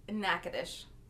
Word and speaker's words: Natchitoches: Natchitoches.